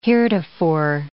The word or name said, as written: heretofore